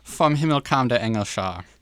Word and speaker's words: Vom Himmel kam der Engel Schar: Von Himmel kam der Engel Schar? – Very close. Vom Himmel kam der Engel Schar.